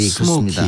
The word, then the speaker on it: Smokey: S-M-O-K-E-Y. – 네. – 그죠? – 예, 스모키. 그렇습니다. – 근데 예. 이게 이름이 나중에 바뀌죠? – 예. 예. 아이 E로 바뀌죠. – 아이로. 근데 네. 발음은 똑같아요.